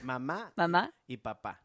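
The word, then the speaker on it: Mamá y Papá: Mama. Y Papa. – Y Papa. – Yeah, the accent is on the last syllables.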